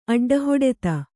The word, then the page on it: ಅಡ್ಡಹಿಡಿತ: kannada